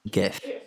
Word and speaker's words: GIF: GIF. – The guy who invented the word said it's a soft G. It's GIF.